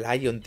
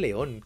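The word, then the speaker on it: Leon: No se dice Lion, tío, se dice León. ¿Cómo que Lion? – ¿El, León? ¿no? Pero es que es León en inglés también.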